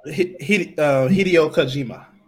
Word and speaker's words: Hideo Kojima: That the creator of the series, Hideo Kojima. – Hideo Kojima.